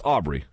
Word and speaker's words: Aubrey: And I pronounced it correct correctly, didn't I? It's Aubrey. – Yes, you did. Correct.